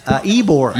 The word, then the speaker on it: Ybor: Ybor.